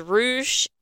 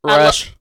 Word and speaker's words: Rush: Fox versus Rouge and, how do you say his partner's name? Dralistico. I got Rouge. – Rush.